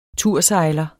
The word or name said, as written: tursejler